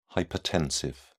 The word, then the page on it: hypertensive: British English